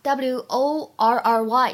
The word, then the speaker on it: worry: 首先的话呢，看一下 w o r r y 这个单词，英式发音当中呢，我们读作 worry，worry，而美式发音当中呢，读成长元音的 a，worry，worry。